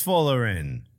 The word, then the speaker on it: Folarin: I don't think it's Flaren, bro. – I'm sure I'm not pronouncing it right. Folarin? Don't know. But it is Folarin. Damn, I've been calling him Florian.